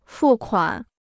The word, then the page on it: 付款: fù kuǎn